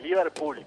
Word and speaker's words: Liverpool: No, de Liverpool, con la sílaba tónica en la última.